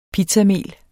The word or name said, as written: pizzamel